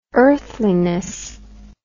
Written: earthliness

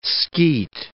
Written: skeet

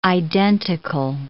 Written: identical

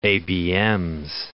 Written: ABMs

